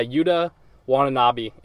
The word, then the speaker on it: Yuta Watanabe: I'm probably going to butcher his name. Yuta Watanabe, I think it is. – That's what I have. Yeah, Yuta Watanabe is how I've kind of heard it pronounced as well.